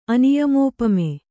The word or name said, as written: ಅನಿಯಮೋಪಮೆ